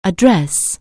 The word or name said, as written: address